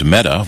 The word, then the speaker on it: Mehta: And in an interesting coincidence, the judge on the case is named Meta, which is right. Google's primary competitor in the ad business. – Right. Except it's spelled M-E-H-T-A instead of just M.